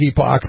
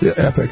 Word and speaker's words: Epoch: But it looks like Epoch, but it's actually pronounced Epic.